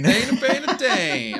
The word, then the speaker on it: Tane: And for listeners, a reminder, my Stam Sork's name is Tane, spelled like Wayne, but with a T.